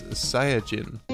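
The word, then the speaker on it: Saiyajin (サイヤ人): This is a reference to the Japanese name and pronunciation of Saiyans, which is Saiyajin.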